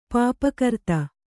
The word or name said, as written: ಪಾಪಕರ್ತ